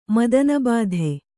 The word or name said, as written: ಮದನಬಾಧೆ